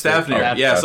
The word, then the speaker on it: Thavnir: But what they're still showing us, like littering the southern border of this map that we have visible, is that you've got is it Thanvir? Like, I can't pronounce it. – It is Thavnir.